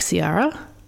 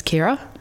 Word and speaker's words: Ciara: I've seen people pronounce her name like the singer, like Ciara, but she was Irish, and so, and I think that they pronounce like C I A R A as Kira.